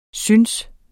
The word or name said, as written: synes